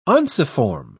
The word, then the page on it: unciform: British English